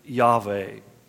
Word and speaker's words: Yahweh: One of the things that happens that becomes a very defining moment is Moses' encounter with Yahweh. And we say Yahweh because phonetically it makes more sense.